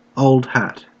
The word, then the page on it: old hat: /ˌoʊl(d) ˈhæt/; /ˌəʊl(d) ˈhæt/